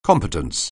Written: competence

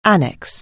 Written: annexation

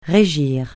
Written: régir

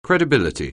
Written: credibility

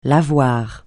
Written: lavoir

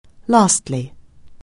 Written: lastly

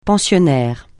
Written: pensionnaire

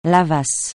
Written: lavasse

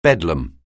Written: bedlam

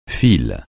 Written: fil

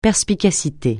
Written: perspicacité

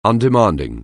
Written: undemanding

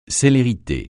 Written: célérité

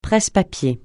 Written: presse-papiers

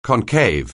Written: concave

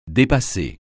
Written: dépasser

